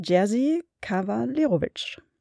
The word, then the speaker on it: Jerzy Kawalerowicz: Wir haben uns angeschaut den polnischen Monumentalfilm aus dem Jahr 1966 vom Regisseur, Aussprache, wir haben das geübt, äh, Jerzy Kawalerowicz.